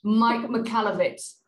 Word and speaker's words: Mike Michalowicz: Mike Michalowicz.